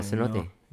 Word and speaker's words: cenote: Yeah, Cenote.